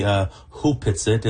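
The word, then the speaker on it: Opitsaht: Tell me about Opetzet Village. What does the name mean? – It's written Opetzet, but it's actually pronounced properly Hupetzet.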